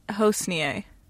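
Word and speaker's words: Hosnieh: Hosnia. – That's not how I like that's easy. It's like Hosier with an N.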